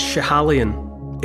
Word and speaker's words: Schiehallion: It's Schiehallion in Perthshire. Schiehallion's a word that it doesn't sound like it's spelt. if you see it written down, you might struggle with it.